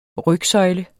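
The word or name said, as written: RYGSØJLE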